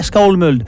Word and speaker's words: Skálmöld: Enfin bon, littéralement, c'est Skálmöld, hein, mais bon, il y a un accent sur le A, donc c'est A-O, et il y a un tréma sur le O, donc c'est E, donc je prononcerai Skálmöld.